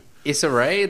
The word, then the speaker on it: Issa Rae: Issa Rae, that's how you pronounce her name,